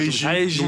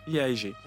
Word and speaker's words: Yaegi: Comment tu prononces alors? Puisque déjà, Yeji. Donc ça s'écrit Yeji. Ça s'écrit Y-A-E-G-I. – Chez les Coréens, comme les Japonais, tu prononces toutes les voyelles, en fait.